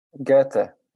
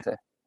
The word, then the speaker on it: Goethe: Goethe. It's it's how you say it. Okay. Goethe.